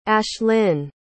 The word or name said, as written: ashlynne